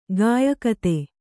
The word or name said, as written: ಗಾಯಕತೆ